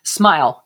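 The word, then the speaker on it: Smial: These Smeal? – Smile. S-M-I-L. – Yes, sorry.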